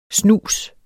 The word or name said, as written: Snusen